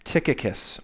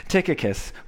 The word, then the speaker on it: Tychicus: Tychicus will tell you, and yes, I looked that up to know how to pronounce it. Tychicus will tell you all about my activities.